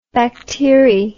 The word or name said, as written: bacteri